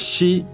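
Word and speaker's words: shi (四): si es el 4, en Japón el 4 es shi y shi también se pronuncia como muerte, por eso es que no tienen eh, el número 4 en los ascensores.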